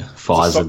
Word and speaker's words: Pfizer: Yeah, it's Pfizer, the silent P.